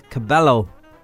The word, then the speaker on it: Cabello: Shawn Mendes and Camila Cabello. Yeah, she told us that's how you say it.